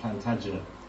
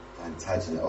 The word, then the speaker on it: Plantagenet: So, I don't know if that name, Plantagenet. – Plantagenet, obviously not. I just said it wrong, sorry.